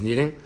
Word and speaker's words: kneading: What's is kneading or kneading, you know, the K is silent, right? Is kneading.